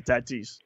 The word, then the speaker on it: Tatis: So, uh, the guy in the Padres, it's Fernando Tatis. You do pronounce the S. You don't uh, leave the last S off for savings. – Right. Okay. Tatis.